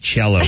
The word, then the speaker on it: cello: My hand wants to know why it's pronounced cello, but there's no H, and my brain just gave him the big...